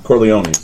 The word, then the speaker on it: Corleone: For The Godfather, we played our version of the Carolinos. Did I say that right? – Corleone's. – Corleone's. There you go.